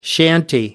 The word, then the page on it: shanty: American English